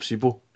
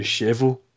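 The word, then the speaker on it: Šebo: My favourite one, I'm pretty sure, everybody knows him as Philip Sebo, but I'm fairly sure his name's actually been Shevo. It's nothing like Sebo. – No, the B's pronounced a V and the S has got a sh instead of an S.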